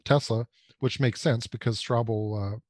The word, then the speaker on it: Straubel: But J.B. Straubel, is that how you say his name? Straubel. – That's how I say it.